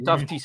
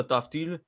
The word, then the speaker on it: Tufte: Puis je me rappelle, les Stars l'avaient fait en 2016, quand ils avaient repêché au premier tour Riley Tufte. Je ne sais pas si tu t'en souviens, Marty. – Ouais, Riley Tufte, ouais, je me souviens. – Ben, Tufte, c'est ça, Tufte.